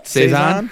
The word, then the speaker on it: saison: Is that Saison? – Make it rhymes with Faison. – Saison.